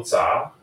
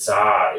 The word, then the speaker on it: Zha: That's Carl Zha. Carl spelled with a C. And Zha is my last name. Z-H-A, Zebra, Henry, Apple. If you want to follow me on social media, my Twitter handle is Carl Zha, like my name.